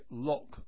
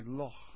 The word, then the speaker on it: Loch: The English say Loch Lomond, with a K in Loch. The Scots say Loch, Loch Lomond, or something like it.